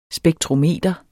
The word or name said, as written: spektrometer